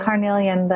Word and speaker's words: carnelian: Yeah, carnelian, the red, it's reddish orange.